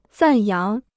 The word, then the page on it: 赞扬: zàn yáng